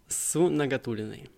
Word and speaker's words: Сунагатуллиной: Ингой Сунагатулиной. Я все правильно сказал? – Да, привет, привет, да.